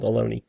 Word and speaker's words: Ohlone: Rhymes with baloney, I'm told, Ohlone.